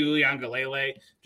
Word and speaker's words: Uiagalelei: Essentially right now he's buying tickets for the Bijan Robinson lottery, DJ Uiagalelei, just trying to, like, position for that.